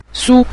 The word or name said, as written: su5